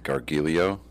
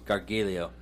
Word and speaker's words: Gargiulo: How do you pronounce it? – Gargiulo. – Gargiulo.